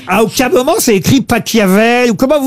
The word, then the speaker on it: Pachelbel: Cannelloni, c'est Pachelbel. – S'il est allemand, ça se dit Pachelbel.